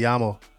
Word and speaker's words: llamo: Me llamo. – Me llamo. – Llamo. – It's two L's. Llamo. – Two L's is pronounced like a Y.